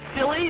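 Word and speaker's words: Cilley: Is it silly? Did I say it correct? – You said it correctly.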